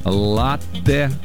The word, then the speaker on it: латте: И знаете, это правильно, потому что слово итальянское, ударение там стоит на первый слог, латте.